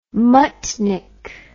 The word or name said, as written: muttnik